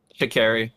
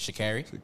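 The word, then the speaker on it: Sha'Carri: Shikari. – That's how you say it. Shikari? – Yeah.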